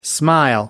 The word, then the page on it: smile: American English